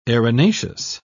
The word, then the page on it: erinaceous: British English